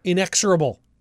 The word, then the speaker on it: Inexorable: Inexorable Tide. – Inexorable. – I said it right.